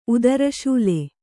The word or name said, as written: ಉದರಶೂಲೆ